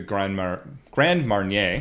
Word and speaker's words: Grand Marnier: So bringing it back to the Grand Marnier.